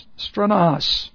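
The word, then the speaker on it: stranos (στρῆνος): All right, S-T-R long E-N-O-S, stranos.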